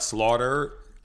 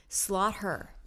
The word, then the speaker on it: Slaughter: But is it spelled like slaughter? – Except with S-L-A-U-G-H-T. – And then H-E-R. – Mm-hmm. – Slaughter.